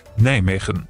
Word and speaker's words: Nijmegen: Ich habe jetzt mal eine offizielle Aussprache gesucht, denn ich sage ja irgendwie so eine Mischung zwischen Nimmwegen, ich nuschel mir da immer gerne eins zurecht. Richtig ausgesprochen, die Niederländer sagen so. – Nijmegen.